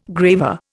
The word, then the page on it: graver: Indian